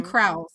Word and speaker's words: Crowell: It's C-R-O-W-E-L-L. – Like Crowell. – Crowell. – I'm not British. I don't know these things. – It's going to be Crowell. I'm going to call him Crowell.